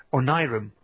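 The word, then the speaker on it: Onirim: I'm also curious to see how you pronounce it, because if you are American cousins seem to think it's pronounced Onirum. – Yeah, which is how the developer of the board game pronounces it.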